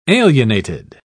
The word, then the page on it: alienated: British English